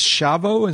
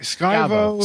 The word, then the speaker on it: Schiavo: It's Chavo? Is that how you say it? – Skyvo. – Skyvo? I don't know. – Skyvo, I think. – Schiavo.